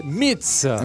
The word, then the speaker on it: Metz: Le nom de ce groupe est Metz. – Voilà. – M-E-T-Z. Ça ne se prononce pas comme Metz. J'ai bien compris qu'il fallait que je ne dise surtout pas le T.